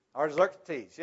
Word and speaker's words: Artaxerxes: Artaxerxes, yes. I have the pronunciation in my outline, but I can't find it right now. But Artaxerxes is the king now.